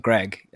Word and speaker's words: Greig: The worst part of actually is the last name, Greg, because it's spelled G-R-E-I-G. Mm-hmm. And I would say around about 106% of people say Greek.